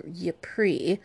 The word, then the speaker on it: Ypres: Shitty Matilda raised forces along with her lieutenant William of, I believe this is pronounced William of Ypres in Kent, and marched to London.